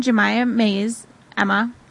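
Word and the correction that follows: Jayma: Did I say that? Or Jema? – I think it's Jema. – I put in an extra syllable.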